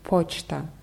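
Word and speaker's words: Pochta (почта): Genau, bei Pochta ist es wieder anders. Da ist die Betonung auf O, deswegen hört man es auch ganz deutlich. Pochta.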